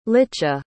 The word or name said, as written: licha